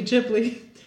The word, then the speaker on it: Ghibli: also interesting that Ghibli, because I wanted to look up how this is pronounced, because I wanted to pronounce it correctly, and I thought, okay, what Japanese word is this based on? – Mm-hmm. – And actually, Ghibli, it's not Japanese, it's Italian, and it's not originally even Italian, it's Libyan Arabic, which is now Italian, which now the Italian language uses, and it means hot desert wind.